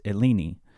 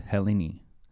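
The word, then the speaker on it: Helleni (Ἕλληνες): It's eleni. Or in the more ancient Greek pronunciation, the word is Helleni.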